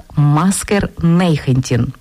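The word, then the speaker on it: Masker negentien: Ця кодова фраза «Маскер 19» вимовляється нідерландською як «Маскер Нейхентін».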